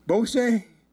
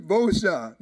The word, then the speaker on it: Beauchamp: Yeah, Brother Keith Beauchamp. Now, around here in Louisiana, we really say Beauchamp. They call it Beauchamp. – Actually, you say Beauchamp. Everybody else cannot pronounce French, so they say Beauchamp or Beauchamp.